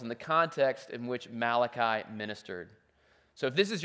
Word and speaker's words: Malachi: Um, it's, it's actually a Hebrew name, and, uh, and it's pronounced Malachi, and it means my messenger.